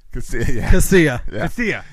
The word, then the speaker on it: Casilla: Alexi Castilla. – Casilla. There you go.